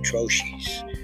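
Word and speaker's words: troche: And oh, by the way, I think I put the Okay, the technical term for what I was calling lozenges is spelled T R O C H E. I believe that's pronounced troches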